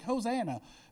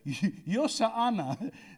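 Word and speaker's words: Hoshana: It's Yoshana, which we get Hosanna from. I know, we butcher it. We're like, Hosanna. Yoshana.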